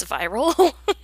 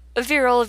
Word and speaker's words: virile: I keep wanting to pronounce virile as viral, which, virile, V-I-R-I-L-E, as in prolific and having many offspring. Not viral, as in V-I-R-A-L, as in carrying a biological pathogen.